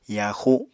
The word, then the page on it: yaaxu: Apatani